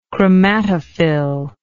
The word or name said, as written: chromatophil